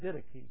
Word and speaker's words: Didache: And so, Webster says you pronounce it Didache. So, that's the way I'll pronounce it.